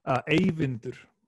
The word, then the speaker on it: Eyvindur: I want to get your name right, and Evander. – Yeah. – Is that right? – Eyvindr. – Eyvindr. Cool. Got it.